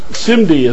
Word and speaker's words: SIMD: The first, SIMD as it's pronounced,